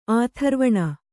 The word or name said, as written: ಆಥರ್ವಣ